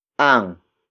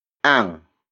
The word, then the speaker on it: ang: For example, nabayaran ang utang namin. Nabayaran ang utang namin. Nabayaran ang utang namin. It means our debt has been paid. Word for word, it's nabayaran ang utang namin. Nabayaran ang utang namin.